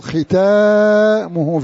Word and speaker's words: ختامه: ألف بعد التاء نطقا لا خطا، الكل يقرأها ختامه مسك، لكن في خط مصحفي تجد ألف صغيرة بعد التاء لا ترسم لكنها تنطق.